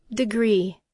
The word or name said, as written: degree